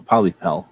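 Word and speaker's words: Pollepel: Oh, Polypel, that's how they say it.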